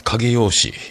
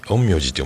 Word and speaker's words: 陰陽師: 陰陽師、影用師、陰陽師って読むんでね。